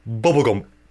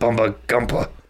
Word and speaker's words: Bubba Gump: It's pronounced Bubba Gump. – That's why I said Bubba Gumpa.